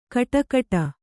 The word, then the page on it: ಕಟಕಟ: kaṭakaṭa